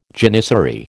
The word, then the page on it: janissary: American English